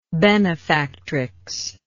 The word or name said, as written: benefactrix